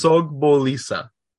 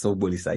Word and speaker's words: Sogbolisa: Try it once. – It's Sogbolisa. – Yes, Sogbolisa, you got it.